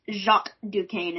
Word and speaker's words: Jacques Duquesne: I liked Jack Duquesne. Is that how you say it? – Sadly, that's how they said it. It's Jacques Duquesne in the comics.